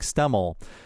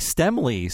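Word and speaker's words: Stemmle: I made myself look foolish because this guy, we talked about him before in other podcasts, and I keep saying Mike Stemmel. His name, based on how it's spelled, should not be pronounced this way, but he chooses to. His name is actually Mike Stemmley. So every time I've said Stemmel, I've said it incorrectly.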